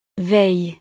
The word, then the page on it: veille: frances